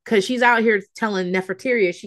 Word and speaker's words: Nefertiti: Nefertiti is tough. – Rhymes with cafeteria. – It rhymes with cafeteria. Like, that's what I kept thinking when I was like, Nefertiti.